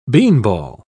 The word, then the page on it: beanball: British English